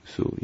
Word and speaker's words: Sioux: Do I say it properly? Sioux? Sioux. Sioux, yes.